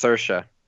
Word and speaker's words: Saoirse: Is it Saoirse or Saoirse? – It's Saoirse. – So it's sure, okay.